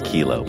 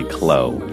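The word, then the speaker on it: KLLO: It's K-L-L-O, which when it's spelled with the lowercase L's, it looks like it's Kilo, but it's actually Chloe.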